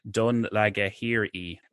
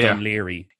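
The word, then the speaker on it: Dún Laoghaire: And then she's like, oh, I have to get back to Dunlagahiri. Dunleary.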